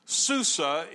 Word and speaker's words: Susa: Most of the events take place in the city of Shushan, which is Hebrew, Susa is the Persian pronunciation.